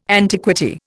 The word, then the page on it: antiquity: Indian